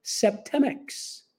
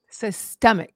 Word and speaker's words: Septemics: Septemics. – Systemic. Am I saying it right? – S-E-P-T-E-M-I-C-S. Okay. Septemics. – Septemics.